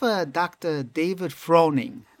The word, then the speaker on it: Froning: It's, I think it's spelled F R O N I N G.